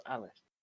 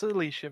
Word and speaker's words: Alicia: I thought it was pronounced as Alice. – It's Alicia Vikander.